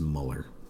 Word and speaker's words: Mueller: I believe it's Muller. Almost like Miller, but Muller. – It's Miller with a U, guys. Muller.